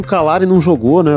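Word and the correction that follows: Calleri: É Caleri o nome dele. Gostei muito da sua pronúncia.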